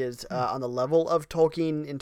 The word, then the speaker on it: Tolkien: He didn't say Tolkien. He's Tolkien. – It's Tolkien. Yeah.